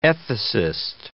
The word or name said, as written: ethicist